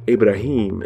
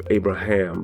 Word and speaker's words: Abraham: And remember, and family, I should say, you remember, because I'm now mentioning it, I say Abraham instead of Abraham because I'm looking to study the Hebrew language a little bit. And pronounce some of the words the way it's pronounced in Hebrew.